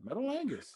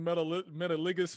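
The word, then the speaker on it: Metalingus: That's how you pronounce it? – Yeah, Metalangus. – I've been calling it Metaligus for the longest.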